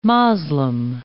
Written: Moslem

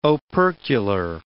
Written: opercular

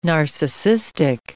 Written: narcissistic

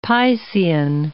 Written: Piscean

